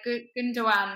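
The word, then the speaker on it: Gündoğan: I held my hands up to it straight away, like Gundogan, which I now know, and I will be pronouncing correctly all season.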